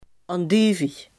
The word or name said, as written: andijvie